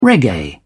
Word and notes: reggae: British English

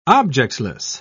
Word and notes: objectless: British English

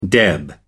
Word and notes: deb: American English

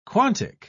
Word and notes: quantic: British English

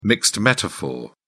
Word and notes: mixed metaphor: British English

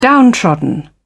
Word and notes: downtrodden: British English

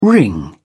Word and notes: wring: British English

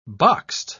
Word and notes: boxed: British English

